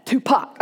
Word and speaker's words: Tupac: I had to make sure I was pronouncing it right, you know. He said, do not mispronounce that. Tupac.